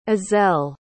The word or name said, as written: ezell